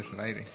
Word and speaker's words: Solaire: Pero lo que le interesa realmente es el amigo de, de Soler. ¿O cómo se dice? – Ah, de Solaire. Solaire.